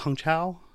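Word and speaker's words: Hong Chau: It's pronounced Hong Chao, right? – Okay, I do think she's really good too.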